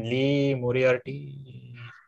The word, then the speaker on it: Moriarty: But anyways, moving on, we had Bobby Fish versus, I'm going to butcher this name, Lee Moriarty. Did I get that right? – Yes, yep, and by the way, Lee, Mr. Lee is all elite.